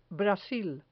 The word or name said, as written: brasil